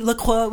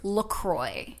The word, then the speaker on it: LaCroix: And LaCroix said, even though, okay, it's supposed to be LaCroix, we get it, it rhymes with enjoy, so it's LaCroix. So while that may not be technically correct, it's correct for the brand.